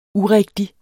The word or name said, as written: urigtig